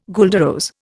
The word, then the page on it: guelder rose: Indian